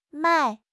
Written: 迈

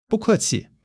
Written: bu4 ke4 qi5

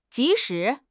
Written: ji2 shi2

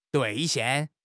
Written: 兑现

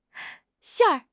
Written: 馅儿

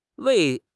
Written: wei2

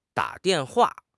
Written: da3 dian4 hua4

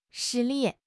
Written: 实力

